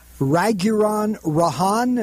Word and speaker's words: Raghuram Rajan: One is this gentleman, Raghuram Rajan.